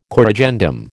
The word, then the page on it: corrigendum: American English